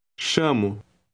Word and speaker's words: chamo: The letter combination CH is pronounced SH in Portuguese. Try to read word number 5. – Chamo.